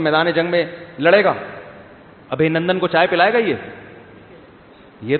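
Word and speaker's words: ابھینندن: کسی نے بتایا ابھی نندن ہے, ابھی نندن نہیں ہے, صحیح تلفظ کیا ہے بھائی, ابھی نندن.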